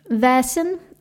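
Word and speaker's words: Vaesen: Is it pronounced Vasen? – Vasen. – Vasen. – Vasen. Yeah, it's like an A-E pronunciation.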